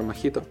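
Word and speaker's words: Mojito: Ну, в Мохито тоже как бы там Моджито пишется, но он же Мохито.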